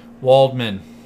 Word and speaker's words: Waldman: Dr. Weldman guy. Mm-hmm. I said it correctly this time, right? – Waldman. – Waldman? – Not okay. Weldman every time.